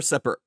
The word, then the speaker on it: Separer: Let's hear it for Separer. Now I'm going to say it wrong. – Step. Oh no, it's so close. – I know, so close. How do you say it again? – Oh, it's Separe.